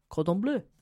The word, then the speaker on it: Cordon Bleu: So she ended up enrolling in the famous French cooking school, Le Cordon Bleu. Cordon Bleu. – Oh, well pronounced.